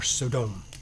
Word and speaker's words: Sodom: We know that he chose to live in Sodom, or Sodom, as some people pronounce it.